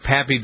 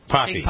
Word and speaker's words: Papi: Big Pappy, Big Poppy, how do they say it? – Poppy. – Poppy. – Poppy. – It's spelled P-A-P-I, though. – Yeah, it is, but in Spanish.